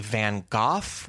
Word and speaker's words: Van Gogh: Apparently, Van Gogh, with a pretty pronounced G, is very common in the U.K. There is one specific Dutch way to pronounce it. It's along the lines of Vincent van Gogh, and then also you will not hear us do that very often.